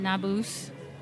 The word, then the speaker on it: Nabus: This is Aaron Nabus. Did I pronounce it right? – Yeah, sort of rhymes with caboose. So yeah, caboose. That's pretty good. – Nabus.